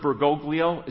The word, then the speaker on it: Bergoglio: In a sermon in 2015, Mr. Bergoglio, is that how you say his name? Close enough.